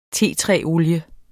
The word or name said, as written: tetræsolie